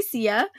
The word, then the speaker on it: Seeyah: So the way that you say it in Chinese is Su Na, but the way that you spell it is S-E-E-Y-A-H. So it's Sia.